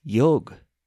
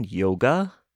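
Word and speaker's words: yog (योग): Simply put, it's yoga. – Don't you mean yoga? I asked curiously. – Nah, she said. That's the 21st century pronunciation. With us, who originated it, it's meant to be pronounced yog.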